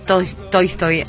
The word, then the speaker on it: Tolstói: Tolstói, ahí lo dije bien, en 1886, como es La muerte de Iván Ilich, hizo un comentario, recomendó leer el libro y todo.